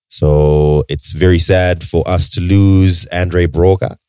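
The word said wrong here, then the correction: Andre Braugher: Is that how you say it? Broga. – Bra. Andre Broga. Bra.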